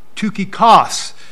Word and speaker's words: Tychicus: I've seen it uh, pronounced several ways. Uh, Tychicus. Uh, the Greek looks like tuka, looks like uh, uh tuki, tukikos, and uh, just different pronunciations.